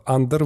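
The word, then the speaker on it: Андор: Да, цифровые релизы, если мы к ним переходим, то в прошлый раз говорили о том, что Андер выходит. Я, кстати, мне было интересно все послушать, как э, в оригинале они его называют. И даже на Википедии написано, что у него ударение на первую гласную Андер.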